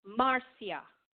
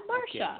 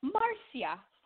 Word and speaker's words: Marcia: But in Jamaica, they say her name Marcia. – Marcia. – It's not Marcia. – Okay. – Marcia. I had this that makes sense. – Friend had to teach me how to say it, and she was laughing at me because I couldn't say it right.